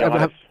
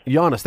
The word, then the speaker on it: Giannis: How do you say his first name? I can't even say Giannis. – Giannis, thank you. Now I'm having a brain block on this. – Think of Yanni.